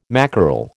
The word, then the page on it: mackerel: American English